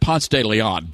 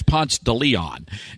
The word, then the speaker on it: Ponce de Leon: It's actually pronounced Ponce de Leon, but if you're in Atlanta, it's Ponce de Leon.